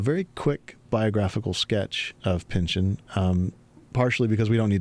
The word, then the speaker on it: Pynchon: How do you say it? – Pynchon. – Pynchon. – Yeah. – I hear that that's incorrect, but it's so satisfying to say Pynchon. – I think that's right.